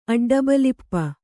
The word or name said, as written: ಅಡ್ಡಬಲಿಪ